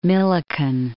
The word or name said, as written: Millikan